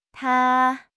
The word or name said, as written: ta1